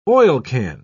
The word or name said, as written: oilcan